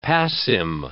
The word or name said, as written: passim